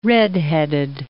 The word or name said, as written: redheaded